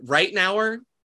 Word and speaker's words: Ritenour: Lee, L-E-E. And then, yeah, Ritenour, I'm pretty sure is how you pronounce it. R-I-T-E-N-O-U-R. – Okay, I got him. – That's like how an Australian says right now.